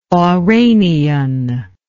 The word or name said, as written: Oranian